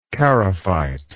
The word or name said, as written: charophyte